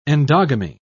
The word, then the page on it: endogamy: British English